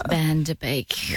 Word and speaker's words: Van Der Beek: James Van Der Beek.